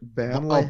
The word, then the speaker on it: Burnley: So, you got to say it like Burnley. – Oh, Barn or Barnley. I think it's Barn. Barnley.